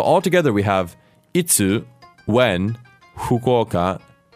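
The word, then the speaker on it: 福岡: So this name starts with a very interesting Japanese sound, fu, which is not exactly a fu, F-U, like it's spelled in English.Instead, it's sort of in between an F and an H. So it's a Fukuoka.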